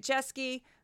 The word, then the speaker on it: Jeske: I'm on YouTube and Facebook under my own name, Juliet Jeske. Um, Juliet spelled like Romeo and one T, so it's just Julie with a T at the end. Jeske spelled J E S K E.